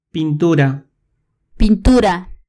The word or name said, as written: pintura